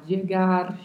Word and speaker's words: llegar: Primeiro, o duplo L da palavra, que tem variações de pronúncia, como já comentamos várias vezes. – Pode-se falar llegar, chegar, llegar, llegar, dependendo da região e sempre o vibrato do R ao final.